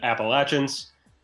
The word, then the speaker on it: Appalachians: I'm mostly excited that you correctly pronounced it Appalachians.